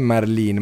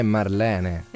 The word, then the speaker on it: Marlene: In realtà prima ci siamo sbagliati, non è Marlene, ma è Marlene. – Esatto. – Abbiamo fatto pronuncia inglese.